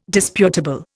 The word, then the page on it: disputable: Indian